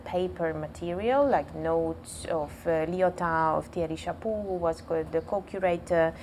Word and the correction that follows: Chapuis: Who is the co-curator? – Thierry Chapuis. – Chapuis. – Sorry for my pronunciation.